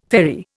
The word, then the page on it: ferry: Indian